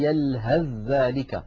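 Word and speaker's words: يلهث ذلك: إذن الحرفان المتجانسان هما الحرفان المتفقان في المخرج والمختلفان في بعض الصفات، نحو قد تبين هم الطائفتان يلهذ ذلك، لاحظوا أقول يلهذ ذلك ولا أنطق الثاء، أثقل الدعوى، وأيضا لا أنطق التاء بل أنتقل مباشرة إلى دال مشددة.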